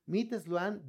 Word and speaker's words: MIT Sloan: Bien, vamos a ver un artículo que publicó Management Review, mit Sloan. Es Meet Sloan Maya, Man, Management Review. Órale, que no, tengo una buena pronunciación. Es Meet Sloan de Management Review. Ah, ¿verdad? Ahora sí quedó bien.